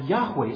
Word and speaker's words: Yahweh: He's saying Jehovah, or Yahweh is the best pronunciation, but the Psalter says it Jehovah.